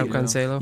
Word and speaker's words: João Cancelo: I think Cancelo, is that how you pronounce it? Yeah, João Cancelo.